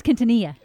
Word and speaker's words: Quintanilla: It's Quintanilla.